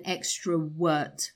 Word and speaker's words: extrovert: So I think you've got to be very careful, Namata, when you pronounce the V sound, right? So you said I'm an extrovert, mm-hmm. – but it should be, what should it be? – Extrovert. – Avert, right? – Mm-hmm. – A V sound.